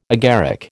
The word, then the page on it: agaric: American English